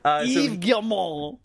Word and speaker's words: Yves Guillemot: I'm just telling you how to correctly pronounce his name. Yves Guillemot.